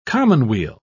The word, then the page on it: commonweal: British English